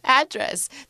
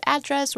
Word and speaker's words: address: 所以这个 address 它有两个发音. I think it can be pronounced as address or address, right? But sometimes as a verb, you pronounce it as address.